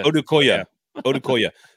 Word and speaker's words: Odukwe: Odukwe is how you pronounce it, apparently, according to Easton. I appreciate the phonetics. – I'll never remember it, so that was a wasted data. – Odukwe.